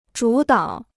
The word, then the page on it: 主导: zhǔ dǎo